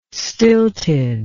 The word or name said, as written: stilted